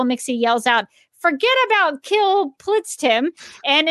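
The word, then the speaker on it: Kltpzyxm: Wonder Woman uses Bizarro's duplicator ray on Mxy and creates a Bizarro Mxy, whose name is actually pronounced Kill Plitztim, because as a Bizarro, his name would be backwards.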